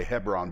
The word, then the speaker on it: Hebron: I'm just going to call it Hebron. It's pronounced properly Hebron, but at Hebron, and said, they said to David, behold, we are your bone and flesh.